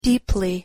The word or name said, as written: deeply